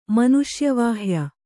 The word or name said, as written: ಮನುಷ್ಯವಾಹ್ಯ